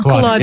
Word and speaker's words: Kolodner: That is the great Baltimore banjo player, Brad, I don't know how to pronounce his last name, Kolodner? Kolodner? – Kolodner. – Yeah, Kolodner.